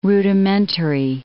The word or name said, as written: rudimentary